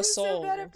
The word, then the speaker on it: Seoul: Seoul? How do you say? – Oh, Seoul. Seoul. – Seoul? – Yeah.